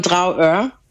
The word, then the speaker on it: Dragør: To myślę, że dobrym wyborem byłoby Trauö, czyli taka dawna rybacka wioska. – Jeszcze raz poprosimy nazwę. – Pisze się Dragør, wymawia się Trauö.